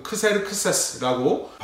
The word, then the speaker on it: Xerxes: 아람식, 그러니까 히브리식 발음으로는 아하스웨로스라고 발음을 하는데요. 그리스 말로는 Xerxes 혹은 크세르크세스라고 발음을 합니다.